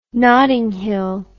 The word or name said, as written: Notting Hill